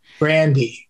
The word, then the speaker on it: Grande: Grandy. Which I learned that's how her name is pronounced, but nobody ever says it that way.